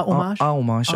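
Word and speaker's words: hommage: Og så hedder det så, udtaler det sikkert forkert, men un homage. – Ja, man skal faktisk bare sige det med et A i stedet for, så det er homage.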